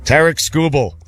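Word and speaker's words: Tarik Skubal: Tarek Skubal. Did I say it right? – Yep.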